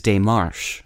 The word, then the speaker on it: démarche: The Word of the Day for April 17th is day-MARSH, spelled D-E-M-A-R-C-H-E. The first E in Démarche is usually spelled with an acute accent rising to the right above the letter.